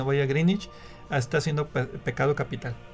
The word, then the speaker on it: Greenwich: O Greenwich, como es la pronunciación.